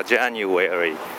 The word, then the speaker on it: January: それで何ページだったかな、 38 ぐらいだったと思うんですけども、月の名前を一緒に受講者さんとやるんですが、 January と言ったら普通 January とこう返ってくるんですけども、あれは綴りとか今まで覚えてた発音に影響されてしまうんですが、あの和のところは Where are you? Where と同じなんですね。だから January、そしてその Where というのも A なんですね。